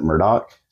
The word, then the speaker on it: Murdaugh: I'm going to say Murdaugh a lot because that's how it's spelled to me. But they kind of pronounce it Murdaugh, so I'm going to try to say both.